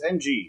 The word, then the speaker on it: Ng: Jane, I want to say her last name is pronounced, like, just Ng. It's Ng.